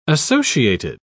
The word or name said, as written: associated